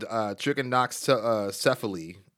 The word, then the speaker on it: trichinocephaly: He says, I had trichinocephaly. Trichinocephaly is how it's pronounced.